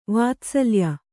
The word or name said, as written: ವಾತ್ಸಲ್ಯ